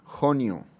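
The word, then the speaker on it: Honjo: En fin, el Premio Nobel de Medicina del 2018 se otorgó a James Allison y Tasku Onyo. Se pronuncia Honio. En fin, es japonés. Tasaku, así se escribe, Onjo.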